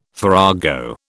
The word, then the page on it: farrago: British English